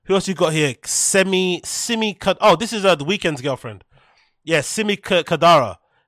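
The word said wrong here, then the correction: Khadra: How do you say her name? Kadra. Simi Kadra. Weeknd's done well for himself, mate.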